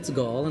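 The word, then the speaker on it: Galle: Is Gaul the right pronunciation? – It's Gaul, and it was a wonderful.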